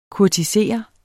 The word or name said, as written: kurtisere